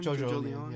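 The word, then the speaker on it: JoJolion: And then I'll leave this last one up to you, because I don't know shit about Jojo Leon. Did I say that right? Is it Jojo Lion? – Jojo Leon, yeah. Like Evangelion.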